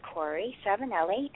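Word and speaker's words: Cori Savenelli: Well, as Sarah said, my name is Corey Savinelli, and I am on Facebook.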